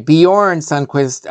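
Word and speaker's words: Bjørn Sundquist: The stranger played by, I guess his name is, I don't know if I'm pronouncing this right, but Bjorn Sundquist. Thank you. That's right. Bjorn Sundquist.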